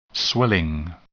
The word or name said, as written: swelling